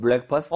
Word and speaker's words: breakfast: 어, 블랙이 아니라? – Breakfast.